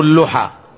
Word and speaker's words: اللحى: قال عدوا الأوامر، أولا قال أكرموا اللحى، اثنين وفروا اللحى، ترى هي بضم الحاء، بضم اللام، بضم اللام، لأن اللحى لحاء الشجر، أي نعم. والصحيح أنها بالضم. اللحية جمعها لحى، مثل مدية ومدى، ومثل زبية وزبى ونحو ذلك، لكن تنطق لحية بالضم بالكسر بالنسبة للمفرد، لكن الجمع لحى.